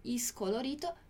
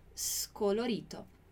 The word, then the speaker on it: scolorito: Atenção à pronúncia do scolorito. Então, não scolorito, scolorito. Scolorito.